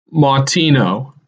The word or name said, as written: Mautino